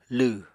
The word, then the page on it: lw: Apatani